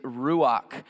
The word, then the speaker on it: ruach: The word is ruach. Everybody together as a class, say it with me. 1, 2, 3. Ruach. You just spoke Hebrew.